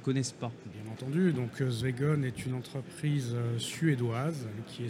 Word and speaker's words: Swegon: Vous êtes euh, directeur commercial chez Swegon. Je l'ai bien prononcé Tout à fait, c'est exactement ça.